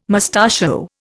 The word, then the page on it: mustachio: Indian